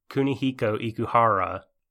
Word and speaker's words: Kunihiko Ikuhara: So Kunihiko Ikuhara, his most recent TV show, Yorikuma Arashi, is basically all about...